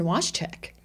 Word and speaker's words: Watschak: I have the one and only Daran Watschak.